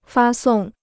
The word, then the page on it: 发送: fā sòng